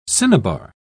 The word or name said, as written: cinnabar